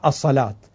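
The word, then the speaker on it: الصلوة: مثل ما تكتب الصلوت فتوضع ألف قصيرة صغيرة على الواو لتشعر بأن هذه الواو لا تلفظ واو وإنما تلفظ ألف فتقرأ الصلاة،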